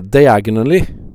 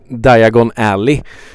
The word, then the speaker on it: Diagon Alley: Eh, och då måste han uttala detta rätt. Eh, för annars kommer han hamna fel. Och vad han säger är diagonally. Alltså han hamnar diagonalt istället för i the Diagon Alley.